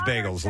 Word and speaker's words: bagels: It is bagels. Long A.